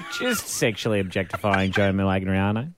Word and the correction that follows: Manganiello: Joe Manganiello.